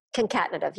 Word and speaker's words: concatenative: Concatenative. – Concatenative, yeah.